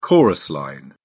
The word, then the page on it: chorus line: British English